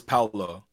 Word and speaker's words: Paolo: It's Paolo. It's not Pablo. Yeah. I know somebody said Pablo, and I was like, what the fuck are you saying? I think it was Paolo. – It's Paolo.